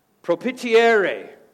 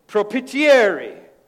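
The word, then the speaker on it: propitiare: Propitiere. You got to say it like an Italian. Propitiere.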